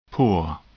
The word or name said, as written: pour